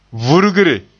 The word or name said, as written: vurgre